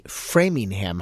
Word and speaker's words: Framingham: That's what you Google, and you'll get a calculator from the National Institute of Health or from the Framingham Heart Study.